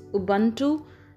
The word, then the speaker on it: Ubuntu: The pronunciation may differ, but as far as I come to know about this, it's Ubuntu, that is humanity towards others.